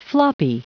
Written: floppy

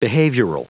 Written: behavioral

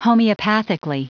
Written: homeopathically

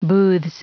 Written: booths

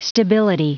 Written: stability

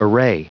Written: array

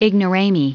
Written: ignorami

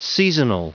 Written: seasonal

